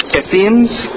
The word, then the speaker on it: Essenes: One is the Sadducees, second one Pharisees, the other one is I don't know how to pronounce this, Essenes. Essenes, okay, thank you.